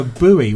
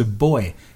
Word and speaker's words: buoy: It's just that in America you call it a buoy when in actual fact it's a boy, because it comes from the word buoyancy.